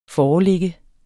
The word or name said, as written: foreligge